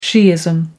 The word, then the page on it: shiism: American English